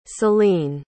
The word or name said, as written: céline